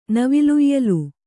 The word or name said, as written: ನವಿಲುಯ್ಯಲು